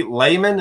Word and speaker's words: Lehman: Coy Lehman, am I saying that right? – Yep. – Lehman. – Yep. – All right, L-E-H-M-A-N.